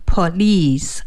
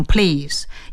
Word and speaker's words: police: 如果我们注意一下我们的警察，包括民警，他的有一个那个臂牌，就是胳膊上面带着一个标志，上面的英文就是 police，一定要念好，念成 police 而不能念成 please，因为 please 就是请的意思，而 police 是警察。